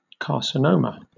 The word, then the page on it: carcinoma: British English